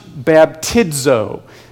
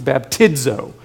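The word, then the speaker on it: baptizo (βαπτίζω): It's pronounced baptizo. In the Greek, the Z makes a DZ sound, so like a diphthong. It's baptizo.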